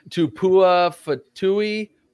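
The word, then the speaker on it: Tupuola-Fetui: And two players, I'm going to butcher their names. I have the pronunciation right here. Zion. No, Zion Tupua Fatui from Washington.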